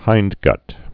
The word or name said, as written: hindguts